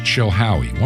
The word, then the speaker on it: Chilhowie: The city is Chilhowee. One word, Chilhowee. That's spelled C-H-I-L-H-O-W-I-E, Chilhowee.